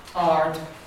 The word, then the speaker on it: ard: A short, a monosyllabic word which has A before RD, RD is a long A. Ard, ard, not ard, ard, ard, something that's called ard, it's a long A.